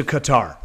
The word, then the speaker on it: Qatar: No, no, she's been to Qatar. I'm sorry. Q-A-T-A-R. I was mispronouncing the Middle Eastern nation.